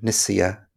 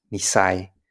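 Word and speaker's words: nissaya: For the third term, affinity or karmic affinity, this is a translation of a Pali word, nisaya, that's pronounced nisai in the Cambodian context.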